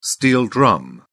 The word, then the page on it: steel drum: British English